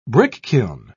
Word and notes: brickkiln: British English

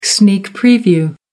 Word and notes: sneak preview: American English

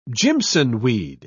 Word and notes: jimsonweed: British English

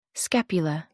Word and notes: scapular: American English

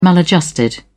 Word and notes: maladjusted: British English

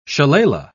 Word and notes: shillalah: British English

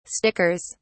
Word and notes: stickers: British English

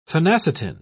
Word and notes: phenacetin: British English

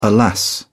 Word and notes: alas: British English

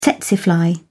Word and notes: tsetse fly: British English

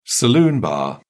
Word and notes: saloon bar: British English

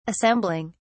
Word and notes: assembling: British English

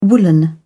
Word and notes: woollen: British English

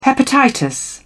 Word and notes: hepatitis: British English

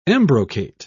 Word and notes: embrocate: British English

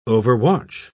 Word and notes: overwatch: British English

